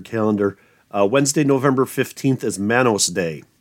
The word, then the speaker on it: Manos: It's pronounced Manos, by the way. – 3 o'clock, The Mads Are Back, Manos, The Hands of Fate.